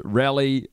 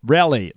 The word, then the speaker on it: Raleigh: Look, can everyone stop getting so butthurt about how I pronounce and Tim pronounces Rally, North Carolina? Rally, like a political event.